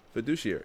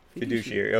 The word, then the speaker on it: fiduciary: Fiduciary. – D-U-C-I-A-R. Yes. Fiduciary.